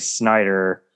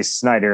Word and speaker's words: Snyder: And I always say Snyder, and they're like, Schneider? And I'm like, you know what, close enough.